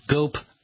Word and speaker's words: GOPe: And the way I remember that is GOPE rhymes with dope.